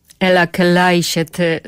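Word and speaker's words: Eläkeläiset: Ela Kleiset z Finlandii, chyba tak to dokładnie się wymawia.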